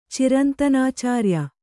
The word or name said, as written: ಚಿರಂತನಾಚಾರ್ಯ